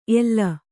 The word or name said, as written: ಎಲ್ಲ